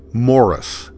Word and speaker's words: Maurice: But my first name is Morris, but it's not spelled. It's M-A-U-R-I-C-E. So most people would say Maurice.